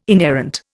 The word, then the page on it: inerrant: Indian